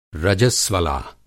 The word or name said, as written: रजस्वला